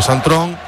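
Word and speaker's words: Sint-Truiden: porque el portero Suzuki jugó Santon, es como lo pronuncian los la otra parte de Bélgica, ¿eh?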